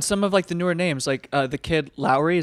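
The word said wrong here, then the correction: Laroi: is that how you say it? – The Kid Leroy. – Kid Leroy, thank you.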